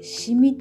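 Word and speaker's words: 紙魚: 紙の魚って書いてシミって読むらしいんですけどもそのあの虫ですねこの虫を捕らえるための仕掛けとかですねそういうことも説明してくれました。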